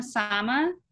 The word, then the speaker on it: Sama: Sama.